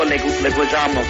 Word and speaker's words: Leguizamo: Yo lo digo como americanizado un poquito, para que nadie lo podía pronunciar. Yo digo Leguizamo.